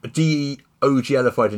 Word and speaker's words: de-OGLified: but they're very deliberately de-OGLified and divorced part this now from D&D. – Yeah. I think you'll find the words that actually pronounced de-oglified.